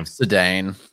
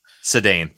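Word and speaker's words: Ceddanne: Sedane Rafaela. It's Sedane. – Thank you. I always want to say Sedan. I don't know why.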